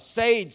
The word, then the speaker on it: Sade: Sade sang it.